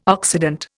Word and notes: Occident: Indian

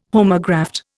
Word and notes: homograft: Indian

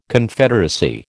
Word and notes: Confederacy: American English

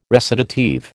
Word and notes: recitative: American English

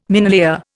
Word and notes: minelayer: Indian

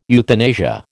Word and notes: euthanasia: American English

